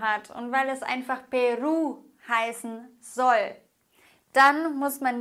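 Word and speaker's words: Perú: Sprich, Peru, haben wir ein Wort, das auf Vokal endet. Okay, Wort endet auf Vokal. Das heißt, wir müssen die vorletzte Silbe betonen. Okay, das würde aber bedeuten, dass wir Peru sagen.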